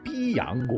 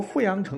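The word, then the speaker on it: 偪阳: 根据《左传· 襄公十年》记载，有一次叔良和随晋、曹、鲁等诸侯联军攻打一个小国，叫做这个字儿第一眼可能会念成逼阳国，逼迫的逼，太阳的阳哈。其实呢，这个逼迫的逼啊，在这里要念成富。小国富阳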